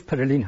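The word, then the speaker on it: Pèlerin: حالا پرلن رو در فارسی میگن ولی تلفظش پرلین هست، Mont Pelerin Society، حالا در فارسی میگن Mont Pelerin.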